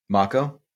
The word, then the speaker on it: Mako: Mako Mako. Did we come to terms on that? – Mako is how I've always heard that pronounced.